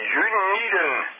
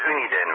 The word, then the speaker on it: Uniden: En die eigenaar die vroeg van, is die Juniden scanner al klaar? Ik denk, Juniden heb ik helemaal niet gekregen. Bleek dat het Uniden was. De U wordt dus uitgesproken als U.